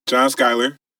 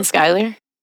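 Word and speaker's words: Schuyler: And I think the last John Skyler? – Yeah. He's from here. – Like, I thought his name is Schuler. – I pronounce it Skyler. I think it's pronounced Skyler.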